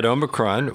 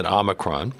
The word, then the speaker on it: Omicron: Some experts have said that Omicron, or as some people pronounce it, Omicron, is almost a different disease.